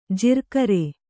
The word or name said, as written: ಜಿರ್ಕರೆ